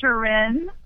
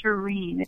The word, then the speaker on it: Shirin: But it's Persian, and there is no N sound in that part of the world. So it's Shirin. It's a long E sound.